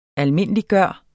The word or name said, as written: almindeliggøre